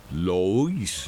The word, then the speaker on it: Louis: Por ahí vendrá a mi memoria, pero este Luis Lois. – De Lois. – Se escribe así, ¿verdad? L-O-U-I-S. Lois.